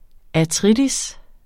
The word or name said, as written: artritis